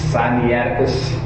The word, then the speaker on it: Sinaiticus: Sinaiticus,